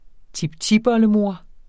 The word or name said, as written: tiptipoldemor